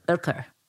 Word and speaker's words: Oetker: Oetker.